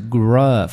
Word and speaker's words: grub: 对，它的发音是 grub，发这个音。